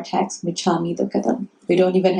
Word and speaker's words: Michammi Dukhadam: Michammi, M-I-C-H. I'll write it down somewhere on the way out. M-I-C-H-A-M-M-I, Michammi, Dukhadam, D-U-K-H-A-D-A-M. Michammi Dukhadam.